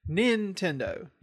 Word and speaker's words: Nintendo: Nintendo. You put the emphasis on the first syllable. Nintendo.